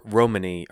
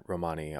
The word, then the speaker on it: Romani: It's spelled R-O-M-A-N-I. They're not connected to the Romans or to Romania, and in their language, the emphasis is on the first syllable.